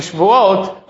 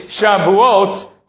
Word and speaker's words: Shavuot: So many people mispronounce the holiday as being Shvuot, but it's really Shavuot.